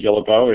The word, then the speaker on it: Guillebeau: So I actually say Guillebeau. It's kind of the anglicized version of the French, which would be Guillebeau or Guillebeau.